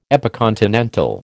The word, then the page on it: epicontinental: American English